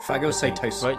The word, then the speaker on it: phagocytosis: Larger particles, smaller than the cell itself, are ingested through photokinesis. – Phagocytosis. – Phagocytosis? – Yes. – Larger pieces. – I tend to go for that pronunciation.